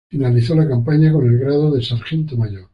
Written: sargento

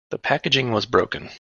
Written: packaging

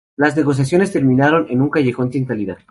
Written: terminaron

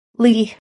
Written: Li

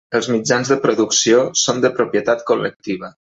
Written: col·lectiva

